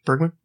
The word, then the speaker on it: Bergman: Bergman, right? – Yes. – Is that how you pronounce it? Yeah.